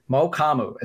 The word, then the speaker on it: Mukuamu: Mokamu is how you say his last name.